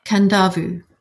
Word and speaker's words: Kadavu: Kandavu. So in Fiji, they're not silent inns, there are invisible inns. So it's spelled K-A-D-A-V-U.